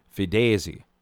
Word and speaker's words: Fides: I thought when I first saw the name of it, it was called The Fides Show. F-E-D-E-S. Or F-I-D-E-S. Fides. Actually pronounced Fidesi, which I guess is Latin for, well, I'll have to look that up again, but I know that it's Latin for something.